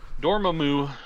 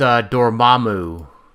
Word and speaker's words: Dormammu: Dormammu. – No, it's Dormammu. – Oh, I apologize. – No, sorry. No, I said it wrong, didn't I? It's you did. Dormammu. – Dormammu. Yeah, it's Dormammu.